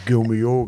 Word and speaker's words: Gault&Millau: Ich hoffe, ich habe es richtig äh, ausgesprochen. Gomio, ganz genau.